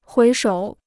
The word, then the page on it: 回首: huí shǒu